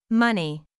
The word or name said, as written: money